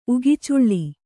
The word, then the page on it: ಉಗಿಚುಳ್ಳಿ: Kannada